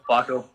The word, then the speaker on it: WAKO: How do you say it? WAKO or yep. WAKO.